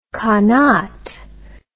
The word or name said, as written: qanat